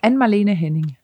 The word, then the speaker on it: Anne-Marlene Henning: Wie spricht man es auf Dänisch aus? – Anne-Marlene Henning. – Anne-Marlene Henning, oder?